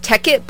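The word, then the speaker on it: Tek It: After that was Tekkit by Kafune, and I didn't mispronounce that, even though I have a crazy stutter as a DJ. It is T-E-K space it, so, you know, that's cool.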